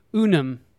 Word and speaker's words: UNUM: I'm sitting here with Dillon Morgan, founder and CEO of UNUM. That's the correct pronunciation, yes? – Yes, it is.